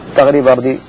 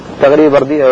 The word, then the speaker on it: تغري بردي: والله هو ينطق هكذا بردى تغرى أو بردي تغري، تغري بردي، هو تركي واسمه ينطق بالياء المقصورة وبالياء الموقوصة، تغري بردي أو تغرى بردى.